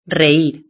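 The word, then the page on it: reir: Spanish (Spain)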